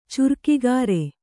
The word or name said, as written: ಚುರ್ಕಿಗಾರೆ